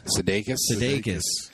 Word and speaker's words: Sudeikis: And then finally, and I'm going to butcher his name, Jason Sudeikis? – Sudeikis.